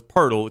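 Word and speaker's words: Poeltl: So because this is a guy with his name rhymes with Wordle, it's Pirtle. It's not spelled like that, but it's pronounced Pirtle.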